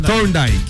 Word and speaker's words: Thorndike: El señor, corrígeme si lo pronuncio mal, Ricky Show, Augusto Thorndike. Así se llama. – Thorndike.